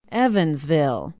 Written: evansville

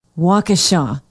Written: waukesha

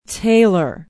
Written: taylor

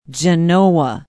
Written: Genoa

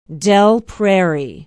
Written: Dell Prairie

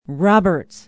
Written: Roberts

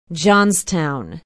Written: johnstown

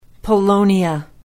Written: polonia

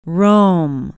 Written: Rome

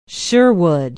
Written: Sherwood

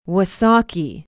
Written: wausaukee

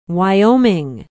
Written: wyoming

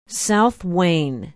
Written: south wayne

